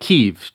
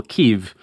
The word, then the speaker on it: Kyiv: So this Ukrainian-style pronunciation, when American officials say it, it often comes out something like Kyiv, just that like one syllable Kyiv.